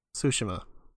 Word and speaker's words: Tsushima: I am playing Ghost of Tsushima. Ghost of. So you didn't wait long enough. – Tsushima.